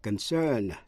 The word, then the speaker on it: concern: Hai từ nữa là concern, C-O-N-C-E-R-N, là quan tâm hay là liên hệ đến, và consent, C-O-N-S-E-N-T, nghĩa là thỏa thuận. Dù là danh từ hay động từ đều nhấn ở vần nhì và phát âm y hệt nhau.